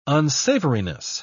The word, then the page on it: unsavoriness: British English